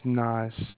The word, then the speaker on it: Naz: My name is Nas. – Mass? Spell it, please. – N-A-Z. – Oh, Naz. Okay.